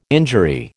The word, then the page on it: injury: American English